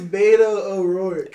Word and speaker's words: Beto O'Rourke: It's Beto O'Rourke. – Beto? Beto? – You trying to throw that R.